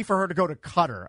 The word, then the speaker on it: Qatar: Is it pronounced Cutter or Qatar? – Cutter, I believe, is the correct pronunciation of the Middle Eastern country.